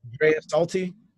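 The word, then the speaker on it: Draisaitl: Dreisaitl. – Leon Dreisaitl for the Edmonton Oilers with 17 goals.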